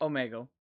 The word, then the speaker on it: Omegle: Omegle? Is that how you say it? – Well, that's yeah. – What is it called? – Omegle.